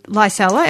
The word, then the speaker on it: Licella: Firstly, can you give us some background on Licella? Am I pronouncing that correctly? – You're pronouncing it perfectly. Some people like to call it Licella, but actually it's spelled Licella.